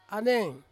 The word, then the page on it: aneng: Ansus